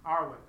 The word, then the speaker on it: Arwen: Arwen.